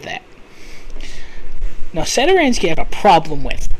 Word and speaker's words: Satoransky: Satoransky.